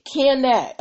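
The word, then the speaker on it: Kaneh: Their name is Canet, and it's spelled K as in kitty, A-N-E-H.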